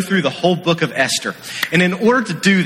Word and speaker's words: Esther: So if you have your Bible, turn to Esther. The H is silent. Esther.